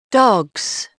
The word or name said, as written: dogs